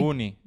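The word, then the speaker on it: Uyuni: La pronunciación no la sé, es desierto de Yunai, Yunai, de Yun. Yuni. – Ah, Salar de Yuni, en Bolivia.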